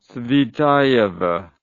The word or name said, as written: Tsvetaeva